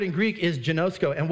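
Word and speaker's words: genosko (γινώσκω): The word know here is pronounced genosko.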